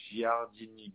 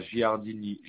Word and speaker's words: Giardini: Alors, Giardini Bianca. – Ça s'écrit comment? – Alors, Giardini, G-I-A-R-D-I-N-I. – Oui. – Et Bianca, B-I-A-N-C-A.